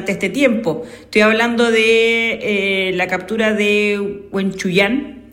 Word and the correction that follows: Huenchuñán: si es que lo pronuncié bien. – Sí. Jorge Huenchuñán.